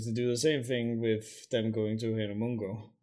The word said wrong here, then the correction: Hueco Mundo: or whatever, how you pronounce it. – Hueco Mundo.